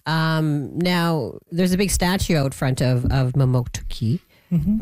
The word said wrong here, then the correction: Mamoktokati: Am I saying that right? Mamoktuki? – Mamoktokati. – Mamoktokati. Oh, I missed something in there.